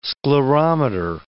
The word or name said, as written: sclerometer